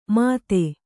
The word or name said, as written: ಮತ್ತೆ